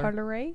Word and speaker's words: Parler: Don't you parlay on Parlor? – Parlay. – It's Parlay. – Oh, it's spelled Parlor, but it's Parlor is how you spell it in French.